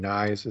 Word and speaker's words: Knies: I believe it's Matthew Knies is how you say his name.